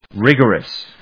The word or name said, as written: rigorous